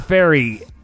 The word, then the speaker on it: Fairey: Shepherd Fairey, S-H-E-P-A-R-D, Shepherd Fairey, F-A-I-R-E-Y.